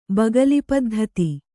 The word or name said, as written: ಬಗಲಿಪದ್ಧತಿ